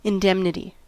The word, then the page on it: indemnity: American English